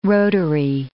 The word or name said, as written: rotary